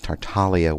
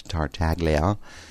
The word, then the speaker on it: Tartaglia: I'm glad that I now know that it's Tartaglia wine, not Tartaglia. The G is silent.